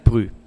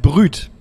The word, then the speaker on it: brut: Ja, pass auf, es heißt nicht Brü. – Oh. – Nein, es heißt Brüt.